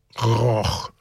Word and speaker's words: Gogh: Gogh, the Dutch pronounce it somehow like that.